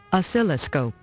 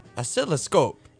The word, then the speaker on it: Oscilloscope: Oscilloscope. – Oscilloscope. – Indeed. – I just love the way that that woman sounds. Anyhow, Oscilloscope Laboratories started off in 2002, and it was actually a recording label.